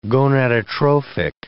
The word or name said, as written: gonadotrophic